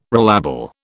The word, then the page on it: relabel: British English